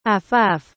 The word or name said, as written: afaf